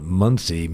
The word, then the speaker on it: Munsee: And Muncie Meats is not spelled like the town in Indiana. It's spelled yes. like M-U-N and then C, like looking with my eyes. M-U-N-S-E-E.